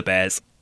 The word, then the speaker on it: BAER: Alaska found a home at Maryland Zoo, Baltimore, where she was described as one of the calmest bears staff ever worked with. It eventually dawned on staff to perform a BAER test on her. This is pronounced bear.